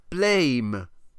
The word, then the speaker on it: Blame: And it's called Blame. – Blame. – !! But actually, that should be pronounced Blam, as it's a reference to sort of cartoony, you know, blam, kapow, smash, boom type comic sound effects.